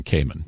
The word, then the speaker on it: caiman: Cayman. Mm-hmm. Is that the proper way to say yes, this? Yes, it is. Okay. C A I M A N, Cayman.